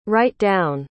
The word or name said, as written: write-down